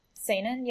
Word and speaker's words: seinen: Like there's I guess there's not really anything like seinen, is that how you say it? – Yeah, seinen.